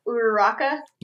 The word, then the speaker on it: Uraraka: I like Uraraka. – Yes. – Mm-hmm. – I never know if I'm pronouncing her name right, but... – Yeah, that's correct, yeah.